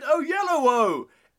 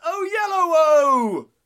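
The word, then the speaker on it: Oyelowo: But I say, less YOLO, more David Olilowo. It's God. David... So I mangled his pronunciation. – No, that was coming out right. – But I say less YOLO, more David O'Yellowo. It's David O'Yellowo.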